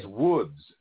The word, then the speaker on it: Łódź: And he said that L with the line through it is pronounced as a W in English. And so it's Łódź, Poland, Łódź.